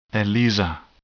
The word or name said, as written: ELISA